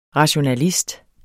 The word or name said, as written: rationalist